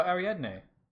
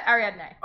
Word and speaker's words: Ariadne: Her name's Ariadne. – Oh, Ariadne. – Yeah, Ariadne.